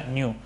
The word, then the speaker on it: GNU: Para denominar este proyecto, ¿no?, de crear este sistema operativo, y pues le puso un nombre que le, pues es GNU, ¿no?, que se pronuncia New.